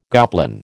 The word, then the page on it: goblin: American English